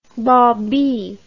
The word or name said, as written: baubee